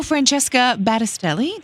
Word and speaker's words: Francesca Battistelli: It's He Knows My Name by Fratis. I'm not sure how you pronounce her name at all. – Oh, Francesca Battistelli?